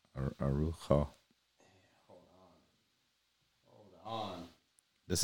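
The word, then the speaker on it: Araújo: It's basically air. Ojo, Ejo, and the emphasis Arujo. is on the O, but. Uh, um, uh, Arujo.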